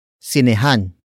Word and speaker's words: sinehan: And that word is sinehan. Sinehan. Sinehan. Sinehan is spelled as S-I-N-E-H-A-N.